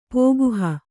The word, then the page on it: ಪೋಗುಹ: Kannada